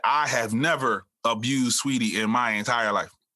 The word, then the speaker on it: Saweetie: I hate saying Saweetie, but her name's Sweetie. His name is Sweetie, like, but it's spelled Saweetie, but Saweetie, like the cereal.